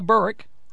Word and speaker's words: Berwick: Yep, Becca Berwick. Only one syllable in Berwick, apparently. – That's exactly right. – And then tomorrow. – It's like Trenton.